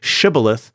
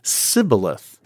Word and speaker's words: Shibboleth: Well, it turns out, for whatever reason, that the Ephraimites have a dialectical quirk where they cannot say Shibboleth, but instead pronounce it Sibboleth.